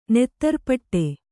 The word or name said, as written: ನೆತ್ತರ್ಪಟ್ಟೆ